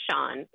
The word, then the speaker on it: Chaon: So Dan Shaun, and I want to spell that name because it's not spelled like it sounds. It's Chaon. He is one of my favorite writers, and he's a current writer.